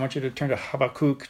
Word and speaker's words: Habakkuk: But really, the Hebrew pronunciation of this word, its name, is Habakkuk.